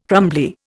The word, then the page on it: crumbly: Indian